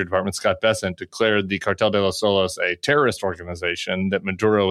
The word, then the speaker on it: Cartel de los Soles: which is the alleged Cartel de los Soles. I don't know if I'm saying that correctly. – Absolutely.